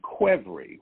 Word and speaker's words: Qvevri: What you want to find is something that is made in Quevri, which is spelled Q-V-E-V-R-I.